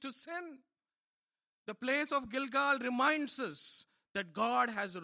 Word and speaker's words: Gilgal: Verse 9. Gilgal. Gilgal. That's what it says. That's how it is pronounced.